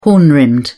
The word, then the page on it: horn-rimmed: British English